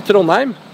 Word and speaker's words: Trondheim: Oh, Trondheim.